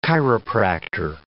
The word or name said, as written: chiropractor